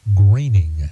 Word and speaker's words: Groening: Matt Groening, the cartoonist, whose name is pronounced Groening, Joel, Matt Groening.